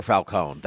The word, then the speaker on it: Falcone: I prefer Falcone. That's the way it's supposed to be pronounced.